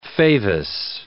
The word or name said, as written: favus